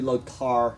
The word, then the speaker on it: Lothar: It's actually Lothar, but we pronounce the TH, they don't. It becomes hard.